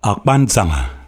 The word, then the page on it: Akpansanga: LHHLL